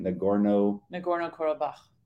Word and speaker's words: Nagorno-Karabakh: Nagorno-Karabakh. – Nagorno-Karabakh.